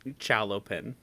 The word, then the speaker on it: Chalopin: It was formed in France in 1971 by Jean Chaplin. Chalopin. – Chalopin. That's definitely the French pronunciation.